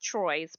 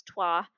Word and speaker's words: Troyes: so it looks like it's pronounced Troyes, but it's Troyes.